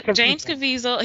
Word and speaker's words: Caviezel: James Caviezel.